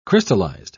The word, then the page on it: crystallised: British English